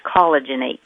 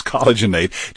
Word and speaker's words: Collagenate: It's collagen. It's collagenate. So there's an A-T-E on the end, just like rejuvenate, but it's collagenate. – Exactly. So it's collagenate, just like rejuvenate.